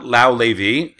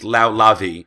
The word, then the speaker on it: Lavi: It is not Lau Levi, it's Lau Lavi. And I always heard other people say his name, Lavi, and I assume they were wrong. It has come to my attention that no, I was wrong.